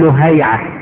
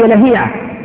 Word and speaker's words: لهيعة: فمثلا تلاقي واحد يقول لك ايه عبد الله بن لهيعة، هي لهيعة، طيب لكن ضبطها الاسماء اخذت سماعا، ما لها ما تدخل تحت القيام.